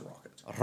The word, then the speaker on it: Rokit: But, Hughes has been doing stints as Rokit's reserve driver in Formula E, as well as, some stints in F3 and Mercedes. – Pretty sure it's just pronounced Rocket.